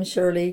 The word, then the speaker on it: Shirley: I'm Shirley Grindle, married to Carpal Zimmon Jr. And correct spelling of your name? – SHIRLEYZIMIN.